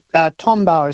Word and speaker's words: Tombaugh: And Tombaugh is the pronunciation that I usually say. – Tombaugh. You know, I did wonder if it would be bar or bow at that rate.